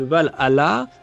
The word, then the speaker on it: Valhalla: Euh, mesdames, est-ce que dans ce, euh, ce Valhalla, vous avez vu que je le dis bien maintenant, hein, Merci.